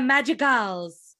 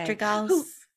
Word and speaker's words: Madrigal: Encanto tells the tale of an extraordinary family, the Madrigals. I think I said that wrong, but it's okay. – Madrigals.